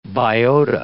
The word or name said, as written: biota